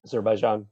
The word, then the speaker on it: Azerbaijan: You guys talked about recently how do you pronounce it? Azerbaijan conflict? – Azerbaijan, yeah.